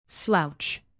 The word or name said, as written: slouch